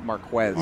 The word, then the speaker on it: Marquez: I think it's Marquez.